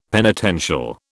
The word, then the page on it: penitential: British English